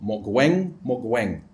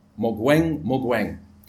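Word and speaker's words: Mogoeng Mogoeng: The Chief Justice of South Africa is called Mogweng Mogweng. If you want to spell it, it's easy, because if you spell his first name, you get his second name thrown in. M-O-G-O-E-N-G. Mogweng Mogweng.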